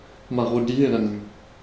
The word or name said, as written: marodieren